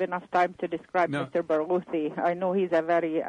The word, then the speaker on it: Barghouti: There's a gentleman, Mr. Barghouti is one of the sponsors, is he not? – Mr. Barghouti? – Did I get his name right? A Palestinian? – That's right. You did have, Barghouti, yes.